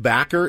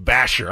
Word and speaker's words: Bachar: Lake Backer is a pitcher for the Sod Poodles. – Is that how you say it? – Lake Backer. – B-A-C-H-A-R.